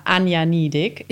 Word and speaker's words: Ania Niedieck: Du bist meine tolle Kollegin Anja, Anja Niedig.